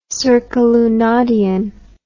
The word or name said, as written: circalunadian